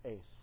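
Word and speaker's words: ace (εἰς): But he uses the Greek word, some pronounce it differently, I pronounce it "ace." Epsilon, iota, sigma. Ace.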